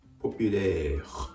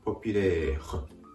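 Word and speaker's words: populaire: ポピュレー、ポピュレー。 R の発音で単語が終わっています。やや発音が分かりづらいかもしれませんが、ふをいうような形で、息をここで止める。発音を止めるような形で、ポピュレー。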